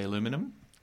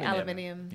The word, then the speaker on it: aluminium: Americans say aluminum. – We say aluminium.